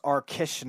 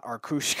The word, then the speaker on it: Arkush: Herb Arkush, pronounced Arkish, not Arkush.